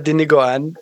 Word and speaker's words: Dinuguan: My second guess would be Dinuguan. I think I said that correctly. Oh yeah, if Dino not, Guan. Yeah, I've had Dinuguan.